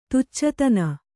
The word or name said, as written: ತುಚ್ಚತನ